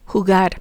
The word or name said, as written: jugar